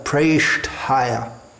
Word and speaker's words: Preshthaya: There may be a tendency to pronounce the T almost as a D, which we find in among some native English speakers. But it should be pronounced very clearly and distinctly, differently from a D, Preshthaya.